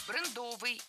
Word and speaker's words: брендовый: Брендовый – это неверно. В этом слове ставим ударение на первый слог – брендовый. Бренд – брендовый. Сохраняем ударение исходного существительного. Кстати, это слово несколько лет назад вошло в словари, и ударение в нем уже нормированное – брендовый.